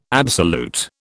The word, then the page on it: absolute: British English